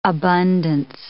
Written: abundance

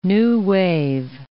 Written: new-wave

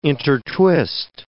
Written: intertwist

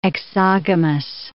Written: exogamous

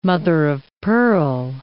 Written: mother-of-pearl

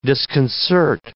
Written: disconcert

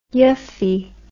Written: yuffie

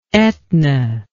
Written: Etna

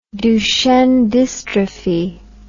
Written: duchenne dystrophy

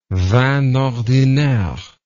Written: vin ordinaire

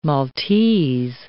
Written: Maltese

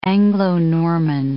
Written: Anglo-Norman

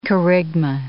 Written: kerygma

